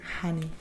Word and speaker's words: honey: Que nosotros lo pronunciamos como una J. – Yeah. – Honey. – Honey. – Honey. Honey, no. – Se dice honey. – Honey. Honey. – It's very soft.